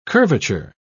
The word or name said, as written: curvature